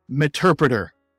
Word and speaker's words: Meterpreter: But the proper pronunciation is meterpreter. Now, we'll hear people say meterpreter, meterpreter. It's meterpreter. The reason we know it's meterpreter is because it is a combination of Metasploit and interpreter. It is the Metasploit interpreter. So Meterpreter, M-E-T-E-R-P-R-E-T-E-R.